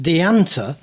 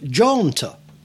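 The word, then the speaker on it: Déanta: This is from a 1993 CD by a Northern Irish band, which we've always pronounced Deanta, but it's actually apparently it's actually pronounced Jaunta.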